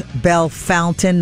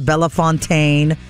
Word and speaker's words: Bellefontaine: Everything from Scioto, it's not Scioto, to Cuyahoga, Coshocton, some tricky other names, Belle Fountain, not Bellefontaine, Olentangy, and Lancaster are among other places with unique pronunciations.